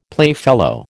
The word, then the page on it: playfellow: American English